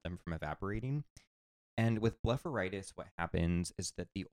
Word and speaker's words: blepharitis: But so it's an eye disease called blepharitis.